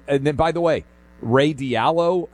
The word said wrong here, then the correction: Dalio: and I may be mispronouncing his name, Dalio, $75 million, $250 each for Connecticut kids.